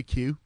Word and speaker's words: queue: Am I saying that right? In the queue. In the it's queue. In the queue. In the queue. – Yep.